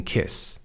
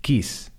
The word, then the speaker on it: kiss: Kiss, this I'm not saying kiss, I'm saying kiss. Kiss is a short vowel sound. A lot of Spanish speakers would pronounce that quis. That would be a long vowel sound.